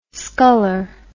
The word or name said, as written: skuller